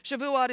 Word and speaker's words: Shavuot: Shavuot